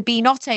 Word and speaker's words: Beanotto: But it's the Beanotto because it's beans and risotto.